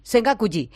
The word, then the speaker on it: Sengakuji: ¿Se pronuncia así? – Sengakuji.